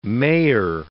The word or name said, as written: mayor